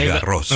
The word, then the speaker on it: Garros: Como ahora que se está disputando el torneo de Roland Garros, usted ya nos había explicado que no es Roland Garros. – No, es Garros. – Roland Garros, sí. Garros, claro, es como la calle Jean Jaurès. – Exacto. – Y esa S final se pronuncia.